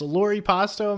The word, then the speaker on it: Loripasto: Am I saying that right? – Yeah, it is. Yeah, yeah. Um, there is like the American pronunciation, which is kind of distorting the name, but it's fine. It's Lori Pasto, more okay.